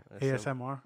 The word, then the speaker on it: ASMR: The SMAR. Or ASMR.